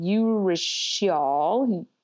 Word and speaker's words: urushiol: But they have something called urushiol, U-R-U-S-H-I-O-L.